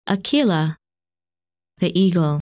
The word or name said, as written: Aquila